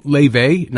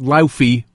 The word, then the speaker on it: Laufey: It was Bewitched from Leve, not Laufey, by the way. L-A-U-F-E-Y, pronounced Leve.